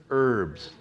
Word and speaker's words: herbs: Take a lamb, an unblemished lamb, and with bitter herbs, silent H,